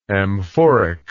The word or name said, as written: amphoric